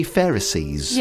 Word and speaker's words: Pharisees: The album, I See Pharisees. – Yes. – Am I pronouncing that right? – You are.